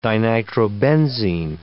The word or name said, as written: dinitrobenzene